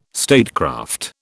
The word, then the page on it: statecraft: British English